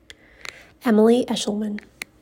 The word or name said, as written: Emily Eshleman